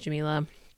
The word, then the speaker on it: Jamilah: But either way, my name's Jamilah, and this is my podcast.